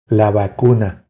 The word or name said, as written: La vacuna